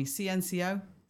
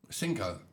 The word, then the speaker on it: CNCO: CNCO? Cinco? – Cinco. – Is it Cinco? – Yeah, five. – No, CNCO. CNCO and Little Mix it is. I don't know if you say Cinco or CNCO.